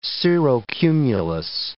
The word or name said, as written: cirrocumulus